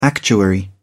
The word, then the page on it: actuary: British English